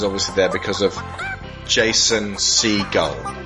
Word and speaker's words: Segel: Segel. – Segel.